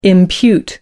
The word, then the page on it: impute: American English